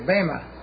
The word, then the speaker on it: bema: And he pronounced it correctly, bema. Because the eta, see, the eta in the Greek is pronounced a, bema. See, it's an eta. Eta, okay. A lot of people say bema, but it's really bema.